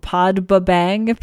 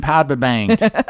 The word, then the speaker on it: Podbbang: It's called Podbabang, P-O-D-B-B-A-N-G. – Yeah, I was trying to figure out exactly how we might pronounce that, and I don't know if, you know, it's called Podbang or... I do like calling it Podbabang. – Well, it's if you pronounce it from an English speaker perspective, right. – That's what it looks like.